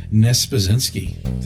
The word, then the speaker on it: Niespodzianski: So today I have my good friend Cindy Nespazinski.